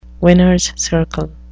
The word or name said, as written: winners circle